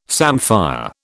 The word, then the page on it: samphire: British English